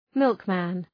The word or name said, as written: milkman